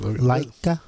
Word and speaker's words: Laika: And this is brought out by, what was the, how did you pronounce it? – Laika.